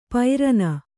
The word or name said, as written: ಪೈರನ